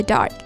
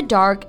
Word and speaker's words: dark: Dark 这个词注意不要读成了 dark，中间的元音是啊 dark，末尾的辅音读得很轻，dark in the dark。keep someone in the dark。 – 嗯，比如说啊，Cici 被蒙在鼓里了。Cici was kept in the dark。她对发生的事情啊一无所知，可以用介词 about。She was kept in the dark about what happened。